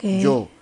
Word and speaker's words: yo: Y yo, se escribe yo, pero la, eh, la, la Y en creor se pronuncia como una I, no como una Y. Yo, sino que se dice yo.